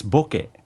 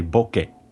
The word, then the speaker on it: bokeh: But it's bucket. It's a very easy word to say, bucket. – But with stunted syllables and a B instead of an O. – Yeah, it's I mean, the E is pronounced like the E in egg,